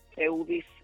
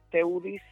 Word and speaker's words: Theudys: Mi nombre se escribe con T de Tito, H de huevo, e de Eddie, U de Uva, D de David, Y de Yuca, a que le gusta mucho, S de Samuel, Teudis Mejía.